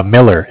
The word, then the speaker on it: Miller: My name is Zach, spelled Z-A-C-H. – Z-A-C-H? – You got it. – What's that name, okay? – Miller, M-I-L-L-E-R, like Miller Beer. – Miller, okay. – I'm calling from Bank of Cardiff. Cardiff is spelled C-A-R, like car, D as in dog, I as in island, F-F as in Frank.